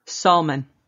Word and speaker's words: salmon: S-A-L. Sal. – Sal. – Mon. – Mon. – Salmon. – No, it's salmon. Drop okay. The L. The L is silent.